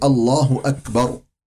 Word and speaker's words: الله أكبر: همزة محققة ليست مبدلة بالواو، يعني الله أكبر.